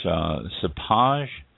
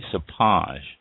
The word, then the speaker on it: cépage: This is, I believe it's pronounced cépage. C-E-P-A-G-E, cépage.